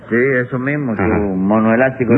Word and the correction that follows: onomástico: No, no, no, su onomástico.